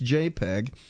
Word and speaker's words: JPEG: Now, JPEG is a standard file format, and it's JPEG, pronounced JPEG.